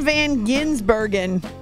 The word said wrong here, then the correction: Gisbergen: No, I think I said it wrong. Gisbergen. I put an extra N in there.